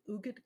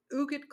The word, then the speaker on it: Huguette: Empty Mansions, The Mysterious Life of Ugud Clark and the Spending of a Great American Fortune by Bill Dedman. So Ugud Clark, which is spelled H-U-G-U-E-T-T-E, and I'm like, how do you even pronounce that name?